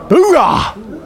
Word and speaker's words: Oorah: How do you pronounce it? Oorah! It's with enthusiasm.